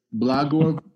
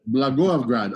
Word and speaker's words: Blagoevgrad: It's Blagoevgrad. Blagoevgrad, yes. – Blagoevgrad, okay.